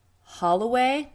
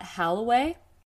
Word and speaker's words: Holloway: It is Holloway, not Halloway, which was a big mistake of mine.